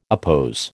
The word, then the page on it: oppose: American English